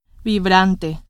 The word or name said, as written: vibrante